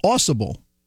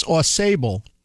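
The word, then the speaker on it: Au Sable: It's a jig, and it's interesting, the story with it, they thought the pronunciation was Awsable, and they recorded it and performed it many times until they landed in the Adirondacks, and someone told them, no, it's Aw Sable. So they took that information forwarded and played in a gig in Michigan, and were told near Awsable, Michigan, no, it's not Aw Sable, it's Awsable. So it depends on where they are, how they pronounce it.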